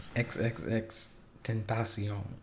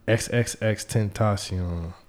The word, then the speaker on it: XXXTentacion: In the midst of all this craziness, they're going to kill XXXTentacion. I don't even know if I'm saying the name right. – It's XXXTentacion. – XXXTentacion.